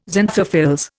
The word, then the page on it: xanthophylls: Indian